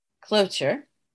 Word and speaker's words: cloture: There is a way to stop a filibuster, of course, some other, you know, thing, which is called cloture. Is that how you say it? – Yeah. – Cloture.